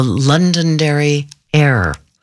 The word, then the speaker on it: Londonderry Air: It's also called the Londonderry Air. I'm always very careful to pronounce it that way.